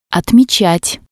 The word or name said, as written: otmetsat